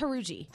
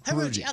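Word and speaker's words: Jauregui: She told us it was Haruji. – Haruji.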